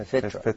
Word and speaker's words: fitra: They say fitra. Did I say it right? – Yeah, fitra.